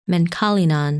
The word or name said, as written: Menkalinan